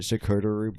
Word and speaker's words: charcuterie: Charcuterie board? Security? – What? – Security. What is it? That cheese board. – Charcuterie? – Yeah, charcuterie board. – He said security.